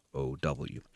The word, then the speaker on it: Mlodinow: which is Subliminal by Leonard Mlodinow. Subliminal, How Your Unconscious Mind Rules Your Behavior by Leonard Mlodinow. I really don't know how to pronounce it. It's Mlodinow.